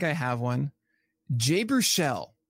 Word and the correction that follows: Jay Baruchel: Jay Baruchel, by the way.